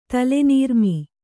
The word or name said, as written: ತಲೆನೀರ್ಮೀ